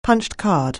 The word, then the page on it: punch(ed): anglais